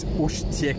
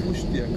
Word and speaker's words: Uštěk: I think it's Uštěk. – Uštěk, okay. – Because they have one E with a hat trick there. – Okay, yeah.